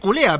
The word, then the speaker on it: Culea: Yo dije, se escribe Culea, pero es Culea.